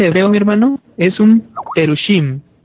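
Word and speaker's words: perushim (פרושים): Entre los hebreos, estos eran, así se le conocía a la antigua, más bien en hebreo, así se pronuncia, los perushim, los fariseos, que es un miembro de la secta judía, que en este caso, todas estas ortodoxas eran con rigor.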